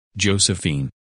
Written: Josephine

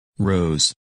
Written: Rose

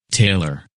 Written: Taylor

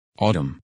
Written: Autumn

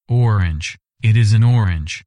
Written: orange

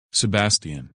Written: Sebastian

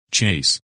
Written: Chase

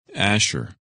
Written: Asher

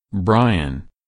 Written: Brian

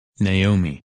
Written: Naomi

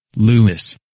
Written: Lewis

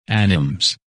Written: Adams

